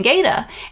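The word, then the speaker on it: gaita: And then later when we got into Galicia, people were saying Gaida. And so we realized that in Portuguese it's pronounced as Lleida, and in Galego it's pronounced as Gaida.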